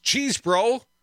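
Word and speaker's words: Chesebro: Cheese Bro, pronounced Cheese Bro.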